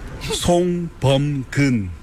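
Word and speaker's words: Song Bum-keun: Song Bom-gun. – Song Bom-gun. – Bom. – Bom-gun. – Kun. – Como Agüero.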